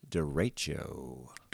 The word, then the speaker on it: derecho: So the weather word, Stormdar weather word of the week this week is derecho. – Derecho. And there's three ways to pronounce this word, but we're going with derecho. Derecho? – Yeah.